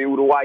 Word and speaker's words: Uruguay: One, they're feeling the role of host, and two, their team, even though they lost badly to Uruguay yesterday, is still going through, and that's pretty exciting.